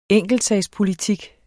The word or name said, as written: enkeltsagspolitik